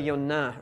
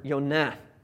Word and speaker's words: Yonah: So his name is actually Yonah or Yonah. All the J sounds that you see in your Bible should be pronounced with a Y sound, because that's how they would have been in Hebrew.